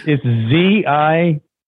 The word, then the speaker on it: Zihuantanejo: So find your Zewantineo. – Zewantineo. Is there, how do you spell that? Is that like? – It's Zihuantanejo.